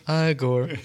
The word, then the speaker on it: Igor: According to Young Frankenstein, it's pronounced Igor, I think. – That's Igor.